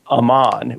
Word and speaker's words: Aman: So in the Silmarillion, there are actually two mythical female characters that talk to each other, and they talk about Aman, which is a holy land of the afterlife, but it's spelled A-M-A-N. So they talk about Aman.